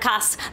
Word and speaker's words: Orcas: There's Lopez Island, there's Orcas Island, which is spelled like the whale, O-R-C-A-S, right. – Orcas, but we pronounce it Orcas, which of course confuses everybody.